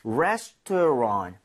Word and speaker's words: restaurant: Restaurant. 여기 R-A-N-T를 랑트도 아니고 랑도 아니라 살짝 run에 가깝게 하면 아. – 발음이 조금 더 괜찮습니다.